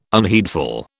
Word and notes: unheedful: British English

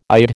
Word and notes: Eid: American English

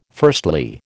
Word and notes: firstly: American English

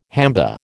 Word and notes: hamba: American English

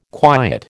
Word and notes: quiet: American English

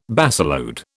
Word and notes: baseload: British English

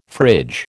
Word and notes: fridge: American English

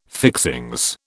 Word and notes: fixings: British English

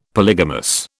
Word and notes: polygamous: British English

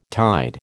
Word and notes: tide: American English

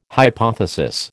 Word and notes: hypothesis: American English

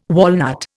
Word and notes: walnut: Indian

